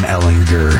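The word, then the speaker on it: Ehlinger: It is Sam Ellinger. Hard G. Not a J.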